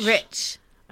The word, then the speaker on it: Reich: I think they said Rich. – Is it Rich? – Rich. – Okay. – Yeah, they're trying to get away from Reich.